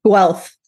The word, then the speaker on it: Guelph: Originally from Southwestern Ontario, she completed her honors bachelor's of science in animal biology for the University of Guelph. Do I say that correctly? – Guelph.